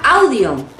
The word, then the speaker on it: audio: Muchísimas palabras que se escriben en inglés con A-U se pronuncian O. – Sí, por fin una regla para la pronunciación que cuesta, ¿no? – Sí. – Encontrar grupos de palabras que la mayoría de las veces se pronuncia igual, pero esta vez sí. – Sí. – A-U se pronuncia O. – Una O larga. – Por ejemplo, la palabra audio. Solamente para acordarte de esta palabra, cómo se pronuncia bien, tienes que aprender a odiar esta palabra.